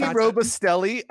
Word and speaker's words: Robustelli: Robusticelli. I think I'm saying it right. Robusticelli. You know the Andy Robusticelli. I think he was a Hall of Famer. – Robustelli? Robustelli. – Robustelli. Yeah, he got it.